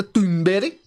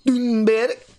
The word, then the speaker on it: Thunberg: You're saying it wrong now. It's Greta Thunberg. Greta Thunberg. That's how she says it.